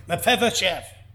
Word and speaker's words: Mephibosheth: Mephibosheth. So I think I've got it. But I'll tell you, I had some practicing to get Mephibosheth out. But eventually got Mephibosheth out.